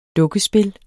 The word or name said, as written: dukkespil